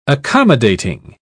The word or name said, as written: accommodating